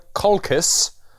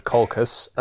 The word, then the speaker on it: Colchis: She gives him a clue, um, tells him to uh, seek Col- Colchis, um, which is spelt really weird and not at all like the word Colchis.